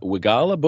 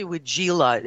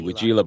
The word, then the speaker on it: Weigela: I want to say got Wigala Bush. – It's actually Wigila. It's spelled not quite right. – Oh, w E I G E L A. – Okay, Wigila Bush.